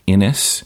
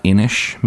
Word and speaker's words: Inis: Innis. Innish, maybe, how it's pronounced? I don't know. – Innis. I think it's Innis. – Okay. Innis or Innish. I-N-I-S.